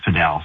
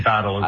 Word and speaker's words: padel: It started in Mexico and became really popular in Spain, and in the Spanish language, it's pronounced padel. So I guess that's the way it should be pronounced, but a lot of people say paddle as well, so either one works.